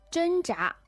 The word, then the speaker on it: 挣扎: 哎，还有啊，我这里要提一下，就是因为刚开始的时候，虽然说这个故事是我写的嘛，但是这个词我是读错的，我是读成挣脱。 – 哦，那你肯定是想起来了，挣扎。 – 对，是的。 – 哎，那么这个挣扎呢，它是第一声，挣脱呢是第四声。 – 挣扎它其实呢是用力的去支撑。